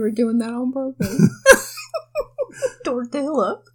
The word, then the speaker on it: tortilla: Like a 6-pound tortilla, kind of. – Tortilla. – How do you pronounce it? – Tortilla. – Oh. Well, I'll fix that then. Like a 6-pound tortilla, kind of. Is that better? – No, way better.